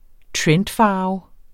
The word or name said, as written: trendfarve